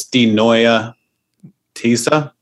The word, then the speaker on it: Die Neue These: It's German, so it's Die Neue These.